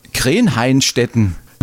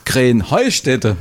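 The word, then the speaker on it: Krähenheinstetten: Ja, ihr saget immer Krenheinstetten. Und dabei heißt es doch Krähenheustädte, oder wie? – Krähenheustädte. – Ja, genau, und das ist die Betonung auf dem Heu.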